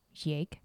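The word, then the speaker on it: Jaeck: Maritime historians Brendan Ballard and Bob Yake, or Jake, sorry for mispronunciation. It's J-A-E-C-K, so Yake.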